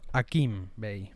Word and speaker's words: Hakim Bey: Yo le digo Hakim, pero es con H, el H es muda. Debería pronunciarlo Hakim Bey, pero para mí es Hakim Bey, como también decimos Tom Cruise.